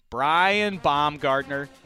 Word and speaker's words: Brian Baumgartner: Brian Baumgartner.